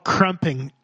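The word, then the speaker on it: krumping: Oh, I'm saying it wrong. It's called crumping. Sorry.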